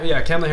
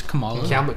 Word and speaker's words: Kamala: Kamala Harris comes in, but here's my view. – It's Kamala.